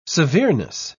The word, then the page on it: severeness: British English